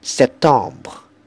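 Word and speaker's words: Septembre: 最尾个 B R E 系会发音，但系就唔需要好重嘅，轻声就得噶啦。所以系 September。 September, 十月 ,October, October, 十一月 ,November, November, 十二月 ,December, December, 咁九月、十月、十一月同十二月，佢哋嘅尾音呢，都係要輕聲。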